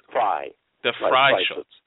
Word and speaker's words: Freischütz: Freischütz. Yes. Yes. – Why is that? I don't want to say Fry because doesn't sound right, but okay. – No, that's the correct pronunciation.